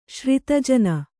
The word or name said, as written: ಶ್ರಿತಜನ